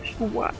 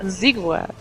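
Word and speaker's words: Ziggurat: Sigurat. Wie wird das eigentlich ausgesprochen? – Sigurat. Ja, Sigurat, glaube ich. So. – Sigurat. – Weiches Z. – Wir sprechen jetzt nochmal aus. Sigurat. – Sigurat. So würde ich es zumindest aussprechen.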